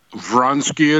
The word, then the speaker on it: Vronsky: And did I get your last name correct? Is that how you say it? – Vronsky it is.